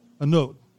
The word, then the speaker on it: Annaud: Jean-Jacques Annaud. – Annaud, thank you. – Is the French pronunciation.